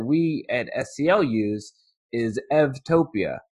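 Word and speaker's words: Eutopia: The reason for the pronunciation is that in Greek, the U is pronounced like a V. And we just, in English, we see the U, we pronounce it like a U, but in Greek, it's pronounced like a V. And so saying Evtopia instead of Utopia, it's easier to distinguish between the two.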